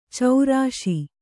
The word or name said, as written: ಚೌರಾಶಿ